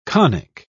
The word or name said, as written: conic